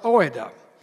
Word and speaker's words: oida: This word that he used that's translated into English as know is the word oida. You would write it out O-I-D-A, but it would be pronounced oida.